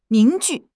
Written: 凝聚

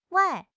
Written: wai4

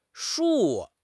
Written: shu4

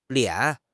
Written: lian3

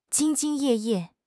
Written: 兢兢业业

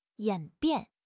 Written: 演变

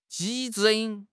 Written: ji1

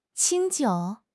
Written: qing1 jiu3

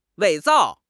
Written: 伪造

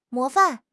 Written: 模范

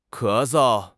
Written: ke2 sou5

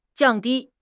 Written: jiang4 di1

